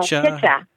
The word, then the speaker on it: Picha: What is Picha? – Oh, Picha. – Picha? – Yeah. – P-I-C-H-A. – Yes. Picha. So, it is Swahili for picture.